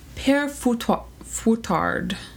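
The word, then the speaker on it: Père Fouettard: I looked up how to pronounce it. The first one is Père Foutard. – That's probably pretty close.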